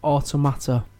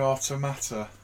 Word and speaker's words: Automata: Because naturally, that should be Automata. – Automata. – For an automaton. – Automata. – Shouldn't it? – Yeah, absolutely. – But they pronounce it Automata.